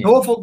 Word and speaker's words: Norfolk: Norfolk, Virginia.